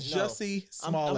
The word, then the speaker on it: Jussie Smollett: Is that really how you pronounce bro name? – His name is Jussie Smollett.